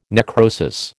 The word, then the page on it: necrosis: American English